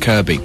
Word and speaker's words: Kirkby: As in, as is the place in Merseyside spelt Kirkby, but pronounced Kirby.